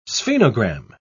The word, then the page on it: sphenogram: British English